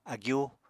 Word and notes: agyú: Apatani

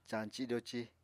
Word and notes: cañci-doci: apatani

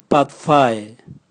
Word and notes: bwatvae: Raga

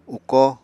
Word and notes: ukó: Apatani

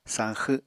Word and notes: sañxw: Apatani

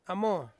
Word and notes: amo: Ansus

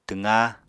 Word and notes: twqá: apatani

